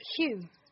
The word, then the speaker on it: Hugh: Hugh. Not who. Hugh the Viscount. Did I say it wrong? – You say it like few. Or phew.